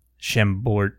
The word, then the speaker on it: Chambord: Chambord? – Chambord.